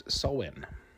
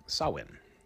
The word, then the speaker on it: Samhain: The pagan festival that looks like it should be pronounced Samhain is actually pronounced Samhain. Samhain.